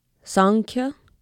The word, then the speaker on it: Sankhya: They are Sankhya, Yoga, and Advaita Vedanta.